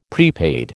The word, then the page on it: prepaid: American English